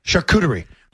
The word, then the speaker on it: charcuterie: Charcuterie.